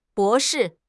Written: bo2 shi4

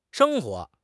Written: sheng1 huo2

